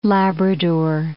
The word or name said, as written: Labrador